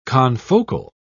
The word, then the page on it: confocal: British English